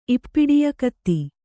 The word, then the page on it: ಇಪ್ಪಿಡಿಯಕತ್ತಿ: Kannada